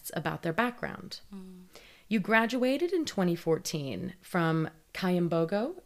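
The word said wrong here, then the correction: Kyambogo: Am I pronouncing that correctly? – Chambogo. – Thank you. Chambogo.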